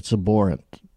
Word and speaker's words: abhorrent: Is it B or P? I know it's spelled B, but I abhor it. – I've always heard abhorrent. – Abhorrent. Yeah. It's abhorrent.